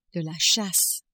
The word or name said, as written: chasse